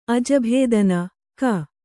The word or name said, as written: ಅಜಭೇದನ(ಕ)